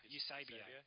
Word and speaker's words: Eusebio: Do you pronounce it Eusebio? Pronounce after the soccer player. Eusebio.